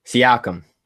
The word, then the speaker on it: Siakam: Siakam.